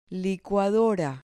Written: licuadora

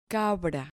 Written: cabra

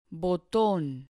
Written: boton

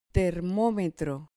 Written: termometro

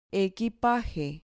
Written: equipaje

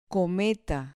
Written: cometa

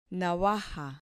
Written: navaja

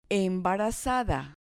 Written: embarazada

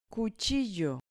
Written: cuchillo

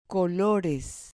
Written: colores

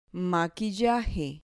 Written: maquillaje

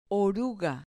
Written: oruga

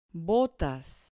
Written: botas

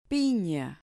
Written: pina

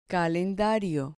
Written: calendario

